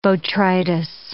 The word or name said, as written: botrytis